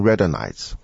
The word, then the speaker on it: Radhanites: 这些犹太人有个特别的名字，这个字很难念的，大概是念成 Radhanites 或者 Radhanites，R-A-D-H-A-N-I-T-E-S。